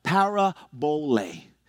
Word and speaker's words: parabole: Now, in Greek, you pronounce this word parable, parabole.